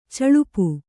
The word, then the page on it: ಚಳುಪು: caḷupu